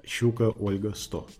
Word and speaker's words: QO-100: Или, как мы радиолюбители произносим, либо Queen Oscar 100, либо Щука Ольга 100. Не, ну, потому что в фонетическом алфавите латинское Q соответствует русской ща, а ща в русском фонетическом алфавите – это щука.